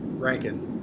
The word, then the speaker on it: Rankin: Rankin.